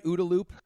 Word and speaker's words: OODA: Am I saying that right? OODA Loop? – Yeah, absolutely. You got it.